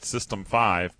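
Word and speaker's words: System V: So Unix Unix space system space V is pronounced System 5, but it's very, very frequently abbreviated S like SYSV, SysV.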